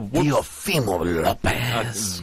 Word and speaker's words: Teofimo Lopez: And, of course, after the fight, Garcia called for a fight with none other than Teofimo Lopez. I hope I'm pronouncing his first name correctly. Who had beaten... – No. – Well... – Teofimo Lopez.